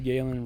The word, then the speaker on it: Galen: You mean Gallen? – Gallen. – Gallen. – Gallen Rupp won. – Carl's can't get past calling him Gallen because of the way it's spelled. – It's G-A-L-E-N. – Gallen. – Gallen. – Yeah. How would you spell Galen? – G-A-Y? – Y-L-Galen.